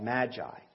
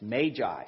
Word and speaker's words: magi: I had to look it up this morning because I was taught magi, but it's magi. So I'll probably say both this morning just to really confuse you. But the magi, they were real people, real wise men, and they were members of a priestly order in the East.